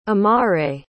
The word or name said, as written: amare